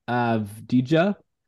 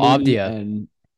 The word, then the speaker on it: Avdija: Denny Avdija. Kevin Avdija.